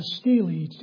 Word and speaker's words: stele: If you read any archaeology, you find out there's this thing called a stele, S-T-E-L-E. And that's how it's pronounced.